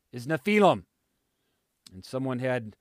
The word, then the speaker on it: Nephilim: And Nephilim is the transliteration. In other words, if you were to pronounce it out in English, that's what it would sound like, Nephilim.